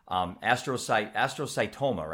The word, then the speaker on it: astrocytoma: There's people watching this that have been diagnosed, astrocytoma, right? – Correct.